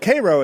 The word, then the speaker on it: Cairo: Now, so people who aren't from Illinois wouldn't know that may think that's Cairo, but Cairo is how people from Illinois pronounce that, and that's at the southern tip of Illinois.